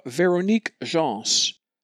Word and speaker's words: Véronique Gens: We will hear the French soprano Véronique Gens. I believe that's how she says her name. G-E-N-S, like Jean, only with the S pronounced.